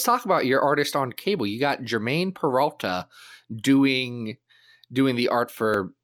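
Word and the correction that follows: Germán: His name is actually pronounced Herman, which nobody knows except for us and our editor, Darren. Yeah, everyone always says he's a Spanish guy.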